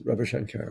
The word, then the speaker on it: Ravi Shankar: So it's not Ravi Shankar? That's not how you say it? – Yes, Ravi Shankar.